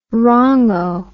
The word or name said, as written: wrongo